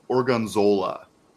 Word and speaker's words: Gorgonzola: How do you say it? Gorgonzola. – Yes.